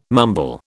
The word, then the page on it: mumble: British English